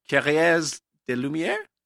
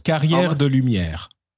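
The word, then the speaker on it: Carrières des Lumières: But in Provence, I went to this thing called, I'll pronounce it wrongly, and then you can correct me, Carrière de Lumière? – Almost. Carrière de Lumière.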